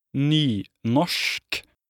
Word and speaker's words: nynorsk: The other, nynorsk, pronunciation nynorsk.